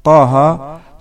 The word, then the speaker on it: طه: والهاء في بداية سورة طه تلفظ طه بلا همزة في آخرها، والراء في ألف لام را في أول سورة يوسف تلفظ را بلا همزة.